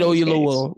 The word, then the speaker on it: Oyelowo: So some of the other stuff is Dennis Quaid signed on to the star opposite David how do you say his last name? Oluyewu. – Hold on, let me see. Yeah. I didn't have this. I turned it off real quick. Oh, David Oyelowo.